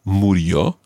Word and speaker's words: murió: Morien, is that how you say it? – Not quite. The verb is morir, and it would be he died, murió.